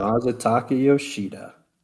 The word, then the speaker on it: Masataka Yoshida: Masataka Yoshida.